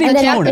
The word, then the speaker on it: Epona: I say Epona. – I've always said Epona. – In Japanese, it'd be Epona.